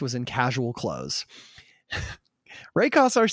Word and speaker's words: Kassar: At the time of our story, Nolan was no longer with the company, Nolan Bushnell, and Warner had appointed someone named Ray Kassar, I hope I'm saying his name right, Kassar, K-A-S-S-A-R, to take over.